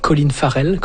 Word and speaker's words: Colin Farrell: Et puis donc, Sugar, ça, c'est avec Colin Farrell. Je sais pas si je le prononce correctement. – Oui, oui. – Colin Farrell.